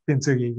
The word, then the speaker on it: Kintsugi: And I apologize if I don't say this right, but is it Kintsugi? – Kintsugi, yes.